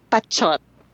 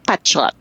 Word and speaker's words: Pachot: It's actually Pachot. – No way! – Yes way. And this is very genius of me. It really is spelled P-A-C-H-O-T, and it's Pachot.